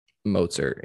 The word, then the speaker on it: Mostert: Behind, is it Raheem Mostert, or tied with Raheem Mostert? – Mozart, yeah.